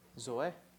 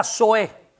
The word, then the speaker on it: Zoe: Zoe. Sorry. Oops. – La vida Zoe. – Zoe. – C-O-E. – Z-O-E. – La vida Zoe. – The Zoe life.